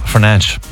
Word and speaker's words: Fernandes: Fernandes.